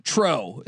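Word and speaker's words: Truex: I did some homework here though, and I'm curious your thoughts on Martin Trow. Is that how you say his name? – Spot on, dude. Spot on.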